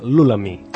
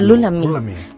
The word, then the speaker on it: Lula Mae: Em sembla que es pronuncia Lulami. – És veritat, Lulami.